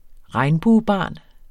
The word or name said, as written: regnbuebarn